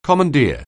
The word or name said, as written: commandeer